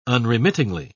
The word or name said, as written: unremittingly